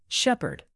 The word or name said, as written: shepherd